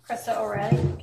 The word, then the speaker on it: Krista O'Reilly: Krista O'Reilly, K-R-I-S-T-A, O-R-E-I-L-L-Y.